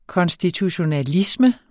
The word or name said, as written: konstitutionalisme